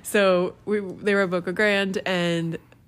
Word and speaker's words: Boca Grande: Brad always says the locals call it Boca Grande. Everyone else calls it Boca Grande. – It is actually Boca Grande.